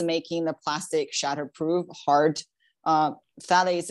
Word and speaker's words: phthalates: Okay, so the next one that we hear a lot about, like phthalates.